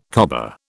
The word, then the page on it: cobber: British English